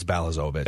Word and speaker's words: Balazovic: Bozlavovich. I can never pronounce this guy's last name. – I think it's Balazovic.